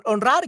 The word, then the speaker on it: honrar: Uh, honrar, yeah, honrar tung means to honor. Correcto, muy bien. So honrar. So as you can see, uh, it's an infinitivo verb, so the a, the h is silent.